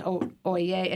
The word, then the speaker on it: Oyez: O-Y-E-Z. OYE. It's pronounced OYE.